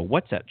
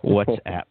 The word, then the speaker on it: WhatsApp: WhatsApp chat. – Okay, there we go. – WhatsApp chat room.